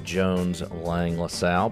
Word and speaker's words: Jones Lang LaSalle: And formerly known as Jones Lang LaSalle, big real estate company.